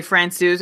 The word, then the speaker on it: Francouz: So that's a situation where you need to weigh the risk of what happens whether you play Francouz or not. And props on that pronunciation, Elon. I wasn't sure we were going to get that one down.